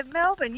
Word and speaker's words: Melbourne: Melbourne. – Yeah. – Yes. Let's go to Melbourne. – Please do. Yeah, Melbourne. You said it right. And lots of people don't say it right.